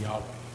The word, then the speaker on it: Yahweh: Y-A-H-W-E-H. Yahweh. But... originally or early on, they messed up and they put an E here, an O there, and an A there, and they pronounced it Jehovah. So the name Jehovah is actually a mistranslation of the word Yahweh.